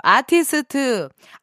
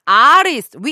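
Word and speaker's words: Artist: 방금 발음 너무 한국 발음이었죠. 아티스트, 아리스트, we are, we are, we 아리스 베이비 요거를 신청하셨군요 아, 근데 진짜로 어린 아기가 있으면요.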